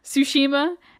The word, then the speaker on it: Tsushima: Awaji, Shikoku, Ikikyushu, Tsushima, and Honshu, which is the biggest island.